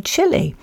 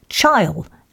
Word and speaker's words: Chile: I remember when I was six, says Norman, back in 1962, and Ollie Beak and Fred were discussing the World Cup in Chile. And Ollie said to Fred he thought Chile had a good chance, only he pronounced it chile, as in pile. And Fred corrected him, saying, it's Chile.